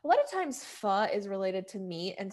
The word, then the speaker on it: pho: and it's pho.